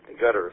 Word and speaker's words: Qatar: They have a number of special calls, A71 FIFA, A72 FIFA, all the way down to A79 FIFA in Qatar, or Gutter, if you want to pronounce it that way.